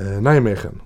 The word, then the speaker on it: Nijmegen: Till Nisch, säger man Nischmegen? – Nijmegen. – Nijmegen.